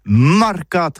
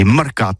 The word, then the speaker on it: marcat: Eh bien, dis ça à tous, mais le mercat de l'Uni ou le mercat des rebelles, mais en gascogne toulousaine, c'est prononcé les marcat avec un A marquette.